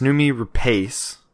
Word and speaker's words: Noomi Rapace: That might be where I start to separate, is I think that because it's Noomi Rapace, did I say that correctly? – Mm-hmm.